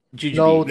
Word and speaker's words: jujube: Footnote, also known as Christ's thorn jujube or the lote so tree. Well, how do you pronounce it? – Jujube. – The Latin version was no problem. The English version. Got ya. – It's jujube. Yeah.